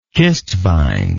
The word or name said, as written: cistvaen